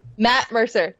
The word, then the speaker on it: Matt Mercer: And I feel like you could probably take on Mac Mercer. Did I say his name right? – Matt Mercer. – That's the one.